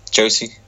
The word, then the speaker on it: Josi: That, I think that's how you say it. – Josi. – Sorry.